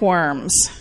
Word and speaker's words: Worms: It's always fun to teach this part of church history to a confirmation class, especially the Diet of Worms, because when you look at it in English, that's what it looks like. It looks like you're having a diet of worms, but it's pronounced Worms.